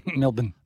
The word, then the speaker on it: Melbourne: But anyway, if you're American, you can say Cranbourne, but it's Cranbourne, like Melbourne. It's Melbourne, mate. – Melbourne.